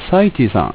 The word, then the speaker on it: サイティ: サイティさん、親友のサイティさんの、名前の由来、あと読み方を教えてくださいと。まあもうね、今言っちゃって分かる通りね、あれはサイティって読みます。